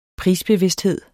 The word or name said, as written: prisbevidsthed